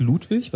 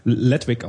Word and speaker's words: Ludwig: Ähm, einer von denen heißt Ludwig, was ich immer sehr lustig finde, weil die ganzen Amerikaner hier Ludwig, nämlich äh, Ledwig aussprechen.